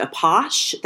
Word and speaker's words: Apache: So before we go any further, I just want to say that the dance is actually pronounced Apache. The characters in the episode we watched this week pronounce it Apache, but for the rest of the episode, I'm going to say Apache because that's the correct original French pronunciation of the dance, just so we're all on the same page.